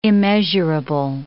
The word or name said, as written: immeasurable